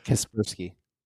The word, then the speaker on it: Kaspersky: Because like how it's written right now within the bill, like Kaspersky antivirus, I'm probably going to mispronounce it, but that Kaspersky. – Thank you.